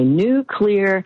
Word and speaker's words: nuclear: And then the third one that people mispronounce all the time is when they say nuclear, not correct. So if it's cloudy today and maybe, but tomorrow it's going to be a new clear day. Just say new clear and you will pronounce nuclear correctly.